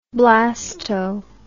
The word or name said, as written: blasto